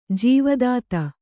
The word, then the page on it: ಜೀವದಾತ: Kannada